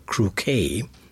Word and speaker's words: croquet: Those who are familiar with a game of croquet, now watch out with that word, it's spelled C-R-O-Q-U-E-T, but pronounced croquet, along the lines of bouquet, etc.